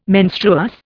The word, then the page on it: menstruous: Indian